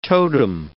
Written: totem

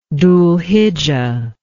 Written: dhulhijjah

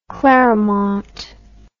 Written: clermont

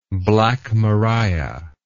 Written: black maria

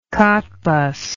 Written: Cottbus

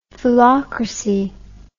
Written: foolocracy

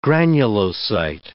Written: granulocyte

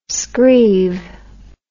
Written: screeve

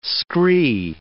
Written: scree

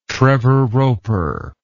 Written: trevor roper

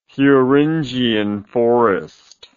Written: Thuringian Forest

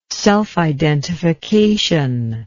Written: self-identification

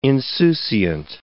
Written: insouciant

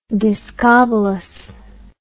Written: discobolus

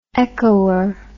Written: echoer

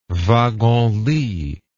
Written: wagon-lit

